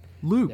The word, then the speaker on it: Luc: It's a Luc Besson. – Luke.